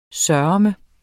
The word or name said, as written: sørme